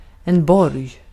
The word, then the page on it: borg: [bɔrj]